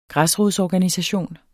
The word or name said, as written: græsrodsorganisation